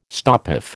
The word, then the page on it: stopoff: American English